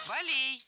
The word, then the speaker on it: болей: Мы же не забываем, у него по-прежнему он страдает от болей в позвоночнике, ему тяжело ходить. – Болей, неверно, правильно, болей. В этом слове тоже оставляем ударение на первом слоге во всех формах. Болей, болем, болями, болях.